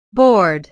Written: bored

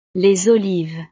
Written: les olives

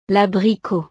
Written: l'abricot

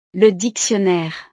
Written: le dictionnaire

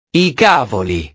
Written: i cavoli

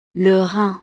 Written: le rein

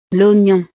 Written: l'oignon

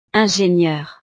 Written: ingenieure

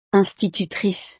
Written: institutrice

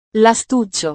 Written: lastuccio

